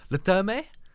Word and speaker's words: Le Terme: L-E-T-E-R-M-E. – That would just be Le Terme. Unless there's an acute accent on the final.